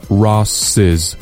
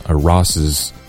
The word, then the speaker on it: Ross's: 结果呢去了哈尔滨佛学院，剃光了头。好了，再来复习一下今天的重点发音解析。我们这里涉及到了一个 This is Ross's thing, Ross, 看加了所有格之后读成了 Ross's thing, Ross's thing, 你不要读两个丝 Ross's, 再比如 James, Charles, Elvis 等等，这种加上所有格之后都会读作 James's, Charles's, Elvis's。